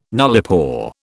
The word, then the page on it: nullipore: British English